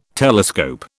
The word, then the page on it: telescope: British English